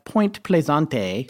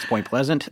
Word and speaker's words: Point Pleasant: like Point Plaisante. No, I'm just kidding. It's Point Pleasant.